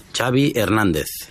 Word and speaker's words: Xavi: Xavi Hernández. – Xavi. – Okay. Yeah, Xavi like chocolate.